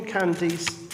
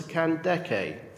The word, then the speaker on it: Kandake: And King Candace, correct pronunciation is probably closer to Kandake.